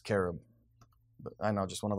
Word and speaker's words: cherub: Well, it's like everyone says cherub, but it's really supposed to be pronounced carob.